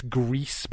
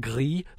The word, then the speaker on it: Gris: And it's and I believe that's why it's Greece, because it's not French, so it's not gris, like the French would like not pronounce the S. I think in Spanish it means grey, and so it's Greece, the S is pronounced, which is, there's a lot of conversation about how you say this game's name when it came out, so.